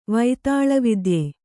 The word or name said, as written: ವೈತಾಳವಿದ್ಯೆ